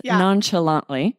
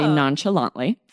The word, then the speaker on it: nonchalantly: I love this word is nonchalantly. Which this sounds very different from how you would pronounce this word in French, nonchalant. But yeah, we just say nonchalantly.